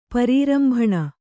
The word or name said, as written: ಪರಿರಂಭಣ